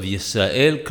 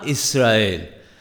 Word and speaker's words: Yisrael: When reading the first words of Shema Yisrael, one must be particular to pronounce the letter Yod of Yisrael correctly. Some people have the tendency to incorrectly pronounce the Yod as an Aleph, or to swallow it and say Shema Israel.